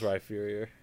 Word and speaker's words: Rhyperior: It was Rhyperior. – Ah. Wow. – The word hype is in the name. You pronounce it, you say the rye.